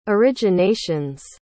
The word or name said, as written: originations